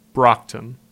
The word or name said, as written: Brocton